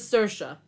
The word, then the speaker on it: Saoirse: It says it's pronounced as Saoirse.